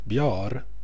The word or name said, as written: bjar